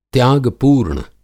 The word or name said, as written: त्याग-पूर्ण